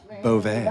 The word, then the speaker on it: Bovee: No, but Bovee, the last name Bovee, it actually, you know, for those who pronounce it correctly, it's Bovee. – Mm-hmm.